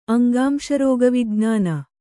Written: ಅಂಗಾಂಶರೋಗವಿಜ್ಞಾನ

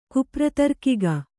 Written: ಕುಪ್ರತರ್ಕಿಗ

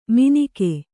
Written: ಮೀನಿಕೆ

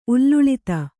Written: ಉಲ್ಲುಳಿತ

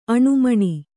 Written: ಆನೆಮೀನು

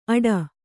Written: ಅಡ

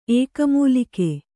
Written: ಏಕಮೂಲಿಕೆ

